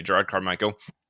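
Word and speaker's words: Jerrod Carmichael: Okay, Gerard Carmichael.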